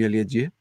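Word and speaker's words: Aliadière: And so I said to him, how do you say your name? He said, it's Jeremy Aliadier.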